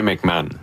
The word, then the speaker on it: McMahon: Sébastien McMahon, de l'Industriel Alliance. – Parfait. Alors, merci de la prononciation. – Comme l'ancien corps arrière des Bears.